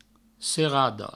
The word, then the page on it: serada: serado